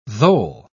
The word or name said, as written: thole